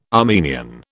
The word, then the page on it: Armenian: British English